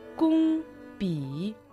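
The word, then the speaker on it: 工笔: Кун произносится в первом тоне, пи в третьем тоне. Еще раз повторю. Се и и кун пи.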